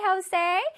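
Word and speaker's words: José: 왜 멕시코에서는 J 발음을 H 발음으로 하거든? 그래서 Jose라고 읽습니다. Happy birthday, Jose!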